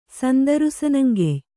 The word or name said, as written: ಸಂದರುಸನಂಗೆಯ್